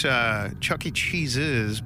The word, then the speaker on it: Chuck E. Cheese's: Which, by the way, people mispronounce a restaurant. Everybody does. – I'm guilty. – It's Chuck E. Cheese's because, like, his name is Chuck E. Cheese and it's his place.